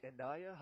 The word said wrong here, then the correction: Anya: Hyatt, is that how you say it? – It's Anya. – Anya. – Anya Hyatt, yep.